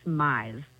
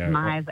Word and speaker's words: Mize: It's Mize. – There you go. – Mize, as in like maximize, minimize, customize is what I always tell people.